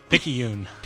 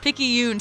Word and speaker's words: Picayune: Picayune. – Picayune.